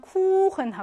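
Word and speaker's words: Kuchen: 예외여서 원래는 법칙으로는 짧아야 하지만 쿠흔 하고 길게 발음한다.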